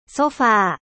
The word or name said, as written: Canape